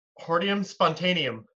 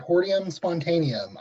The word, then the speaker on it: Hordeum spontaneum: Hordeum spontaneum. – Perfect. Hordeum spontaneum.